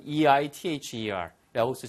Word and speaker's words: either: 나도 뭐뭐 하겠어의 뜻이면 T-O-O, to를 쓰시면 되고요. 못하겠어의 뜻이면 E-I-T-H-E-R 라고 쓰시면 되겠습니다. 그래서 I can't believe it either. 영국식 발음은 either라고 한다는 것도 알아주세요. 그렇죠? – You say either, I say either.